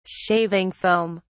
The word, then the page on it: shaving foam: British English